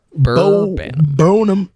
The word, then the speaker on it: Bo Burnham: Bo Burnham.